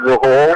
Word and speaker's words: Rechul: I don't know if pronouncing my name is going to help anyone spell it, but you can refer to me as David Rahul. But my first name is actually spelled with a W if you want to Google it, C-A-W-I-D. – Mm-hmm. – And my last name is R-E-C-H-U-L.